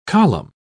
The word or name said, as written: column